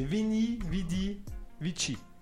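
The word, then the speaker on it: Veni, vidi, vici: Vénus, Veni, Veni, Vici, un truc comme ça. va d'y voir Je sais pas comment on se prononce, mais Vénus, Vénus, Vénus, Veni, presque. C'est Vini, Vidi, Vici.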